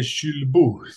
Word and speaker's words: Julbo: Ja, men jag har tränat här i, här i något år, men det är Chulbo, säger eh, fransmännen.